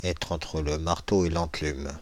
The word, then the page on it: être entre le marteau et l'enclume: France (Île-de-France); /ɛtʁ‿ɑ̃tʁ lə maʁ.to e lɑ̃.klym/